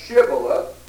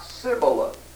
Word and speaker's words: Shibboleth: Shibboleth. And the Ephraimites could not say Shibboleth. Every time they tried to say Shibboleth, it came out Sibboleth.